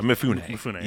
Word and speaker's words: Mifune: I think I said his name right. – Mifune.